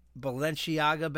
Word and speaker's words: Balenciaga: She was spotted on Tuesday going from her Upper East Side hotel to 30 Rock clutching a large Balenciaga bag.